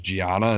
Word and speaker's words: Gianna: I think her name is Gianna, is how you say it.